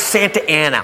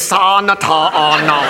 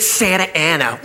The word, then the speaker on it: Santa Ana: It's Santa Ana. What says Santa Ana? Look, I know I didn't finish school, but that frickin' says Sa-na-ta-a-na. They pronounce it Santa Ana. Well, they're wrong!